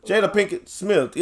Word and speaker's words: Jada Pinkett Smith: Jada Pinkett Smith? Yeah, Jada Pinkett Smith.